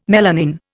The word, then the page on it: melanin: Indian